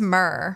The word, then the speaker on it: myrrh: like myrrh. – I think it's myrrh. – Myrrh. See, I told you I couldn't figure it out.